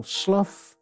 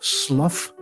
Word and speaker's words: Slough: It was Subtle, S-L-O-U-G-H. Subtle Slough, Subtle Slough. Various radio announcers found it an occupational hazard. Some pronounced it Slough to rhyme with stuff, and some Slough to rhyme with cough.